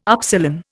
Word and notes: upsilon: Indian

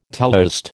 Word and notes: teleost: American English

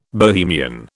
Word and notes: Bohemian: British English

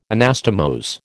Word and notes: Anastomose: American English

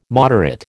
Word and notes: moderate: American English

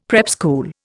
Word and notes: prep school: Indian

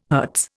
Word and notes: hertz: Indian

